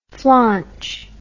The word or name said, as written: flaunch